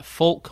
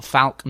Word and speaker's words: Falk: The Light Ages by Seb Falk or Falk, I'm not how you say it, like as in like Falklands, but without Lunds.